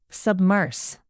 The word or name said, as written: submerse